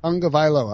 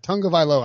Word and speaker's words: Tagovailoa: Tonga Vailoa. – All right. Now I'm good. Now that I got that, Tonga Vailoa, I won't mispronounce it again.